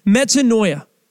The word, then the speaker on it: metanoia: The Greek word for repentance is metanoia.